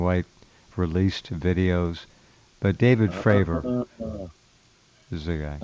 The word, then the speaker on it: Fravor: It's actually not Fave. It's Fravor, which is even stranger.